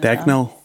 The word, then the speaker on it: Dagnall: Den är då laget samman mellan dig, Torbjörn och Rachel Dagnan. Är det så man uttalar henne? Dagnall. – Ja. Dagnall. Ja.